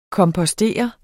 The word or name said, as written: kompostere